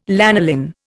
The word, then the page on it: lanolin: Indian